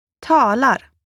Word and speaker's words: Talar: As a general rule, stress in Swedish falls on the first syllable of the word. – Talar.